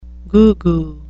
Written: goo-goo

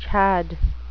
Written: chad

